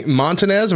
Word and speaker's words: Montanez: All right, well, I am joined by Manny Montanez.